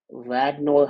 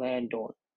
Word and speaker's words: Radnor: I can never get that pronunciation right. Radnor High School there in PA. I used to call it Randor, but whatever.